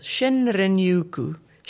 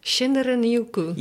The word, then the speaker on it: Shinrin-yoku: Shinrin-yoku. – Shinrin-yoku.